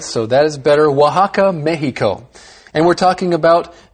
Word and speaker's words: Oaxaca: You see, it's spelled O-A-X-A-C-A, but it's pronounced Oaxaca. – That's right. Oaxaca.